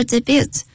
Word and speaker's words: debut: A big day, 重要的日子或者是也可以用大喜之日。来看例句 ,Tomorrow is a big day, we'll make our debut. 明天是重要的日子我们将初次登台。 – 这个字啊 ,D-E-B-U-T 啊一般念成 debut 会来的比较啊就清楚，这来自于法文那个 T 不发音的初次登台讲的 is going to make his debut tomorrow，明天他会第一次亮相这样。